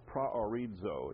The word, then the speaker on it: proorizo: Looks like this, P-R-O-O-R-I-Z-O, praorizo it's pronounced.